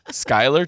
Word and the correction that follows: Sklar: Thomas. – It's Sklar. – It's pronounced Sklar, Thomas.